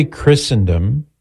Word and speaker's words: Christendom: So we say Christendom with a silent T because some words in English have a silent T, like Christmas.